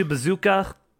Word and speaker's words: BZKA: Best coach went to Bazooka, which is spelled BZKA.